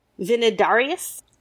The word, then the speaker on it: Vinidarius: And then there is actually a later short version, probably from the 8th century, maybe Carolingian, that was compiled by somebody named Vinidarius. – Okay. – I'm pronouncing the V because this is a bit late. So I'm not pronouncing it as a W.